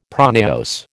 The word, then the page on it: pronaos: American English